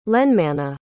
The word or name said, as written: lenmana